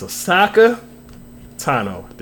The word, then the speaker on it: Ahsoka Tano: Ahsoka Tano.